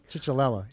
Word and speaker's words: Ciccolella: Ciccolella. – Yeah. Ciccolella? – Yeah. – Ciccolella. – Yeah. – That's C-I-C-I? – C-I-C-C-O-L-L-E-L-A, I think. Ciccolella.